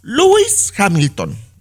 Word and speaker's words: Lewis Hamilton: Y el piloto de la Fórmula 1 Lewis Hamilton.